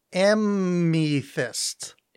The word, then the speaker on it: amethyst: No, I we realized live on the show that I've been pronouncing this word wrong. If you recall, on the WizBiz, there was an episode where I referred to it as amethyst. – Amethyst. – I thought there was an N in there.